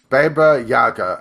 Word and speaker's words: Baba Yaga: Forge the legend of Baba Yaga, a girl accused of witchcraft and expelled from her home.